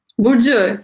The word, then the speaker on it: Burcu: Burcu, you said it right.